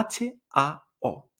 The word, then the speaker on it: hao: Y el sonido inicial de esta, hao, es la H que no se pronuncia como el español, sino que no se pronuncia hao.